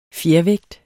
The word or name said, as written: fjervægt